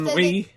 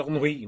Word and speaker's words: ennui: Then, of course, ennui. Is that what you're saying? E-double-N-U-I. That means ennui, which means boredom. It's French.